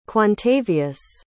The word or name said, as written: quantavius